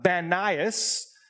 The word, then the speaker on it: Banias: And the place that is called Caesarea Philippi, it used to be called um, this name pronounced with a B, uh, Banias, but specifically that was this place, um, Panias, uh, the word pan for the Greek god Pan.